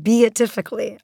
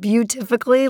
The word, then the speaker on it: beatifically: I realized I had trouble pronouncing beatifically. I kind of said beautifically like 5 times and then I finally corrected it and got it right.